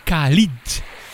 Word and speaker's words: Khalid: Hoor je Ed Sheeran featuring Khalid. Zo spreek je dat uit met hun Beautiful People.